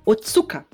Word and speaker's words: Otsuka: e Masahiko Oshi, é, Otsuka. Oshi? Oshi não, Otsuka.